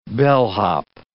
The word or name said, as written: bellhop